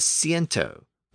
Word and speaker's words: ciento: So the Latin word centum evolved within Spanish and became C-I-E-N-T-O, which is pronounced as ciento, with the S sound. Except in Castilian Spanish, where the sound shifted to more of a TH sound, which is another sibilant. So the pronunciation in Castilian Spanish is more like ciento.